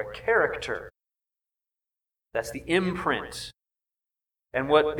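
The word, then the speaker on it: Character (χαρακτήρ): The imprint, the word imprint in Greek is character. Say that. Character. That's literally the Greek word. That's how it's pronounced.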